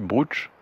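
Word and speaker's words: brocciu: Ça s'écrit brucio, mais ça se dit brooch.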